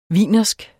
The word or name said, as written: wienersk